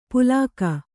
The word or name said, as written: ಪುಲಾಕ